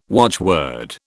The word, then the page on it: watchword: British English